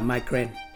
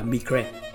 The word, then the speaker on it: migraine: Tiếng Anh đọc là migraine và tiếng Pháp đọc là migraine.